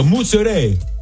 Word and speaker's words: mozzarella: It's rich in antioxidants, fresh mozzarella, or how do they say? – Mozzarella. – They don't even say the A, they go mozzarella.